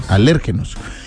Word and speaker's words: alérgenos: Con acento en la E, alérgenos.